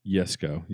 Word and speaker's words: Jesko: So Christian's dad, Jesko, that's how you pronounce it, with a Y rather than a J.